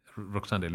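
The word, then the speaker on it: Roksanda: Is that how you say her name, Roksande? – Roksande Lincic.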